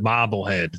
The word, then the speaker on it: Marblehead: And you gotta pronounce it Marblehead. That's Marblehead there. Marblehead.